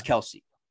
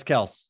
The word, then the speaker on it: Kelce: Kelsey Travis Kelce. Everybody's always been saying his name wrong, Travis Kelce.